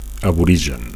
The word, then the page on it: aborigen: Catalan